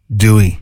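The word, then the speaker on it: Doueihi: Lee, it's actually pronounced Dewey, not Doohehe.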